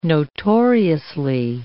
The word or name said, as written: notoriously